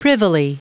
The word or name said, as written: privily